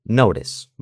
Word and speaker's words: notice: ¿Has dicho que se puede pronunciar como con esa R fina o con T? ¿Puede decir notice o notice? – Eso es. – ¿Se puede decir las dos? – Se puede decir de las dos maneras.